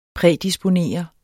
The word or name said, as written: prædisponere